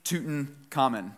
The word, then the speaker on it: Tutankhamun: All right, so in 1922, Howard Carter discovered the tomb of King Tutankhamun.